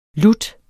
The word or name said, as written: lutter